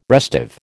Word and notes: restive: American English

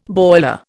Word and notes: boiler: Indian

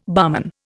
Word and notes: barman: Indian

